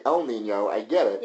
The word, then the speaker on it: El Niño: Like, let's say El Niño, which only people in Miami can pronounce, so everyone else is saying El Niño, if they can even get the Ñ.